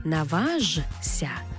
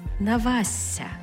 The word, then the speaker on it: наважся: Тобто пишемо «жся», але читаємо «зся», «зся». Тобто не «наважся», а «навасся».